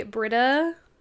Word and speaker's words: Brita: Um, I don't know if anyone's seen the Brita trend. Also, I hope that's how you pronounce it. It is.